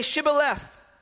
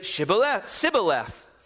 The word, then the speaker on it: Shibboleth: If he said no, then they would say to him, then say Shibboleth. And he would say Sibboleth, for he could not pronounce it right.